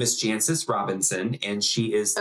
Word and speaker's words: Jancis: It's spelled J-A-N-C-I-S. Jancis, right? It's even better, Jancis. – Janice? – So the C is silent? – I don't know.